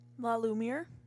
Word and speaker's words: La Lumiere: La Lumiere? – Yeah, La Lumiere.